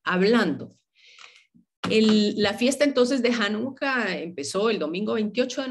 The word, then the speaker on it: Hanukkah: Hanukkah eh, con H se escriben en, hebreo y, y Hanukkah con J en español, pero se pronuncian de igual forma.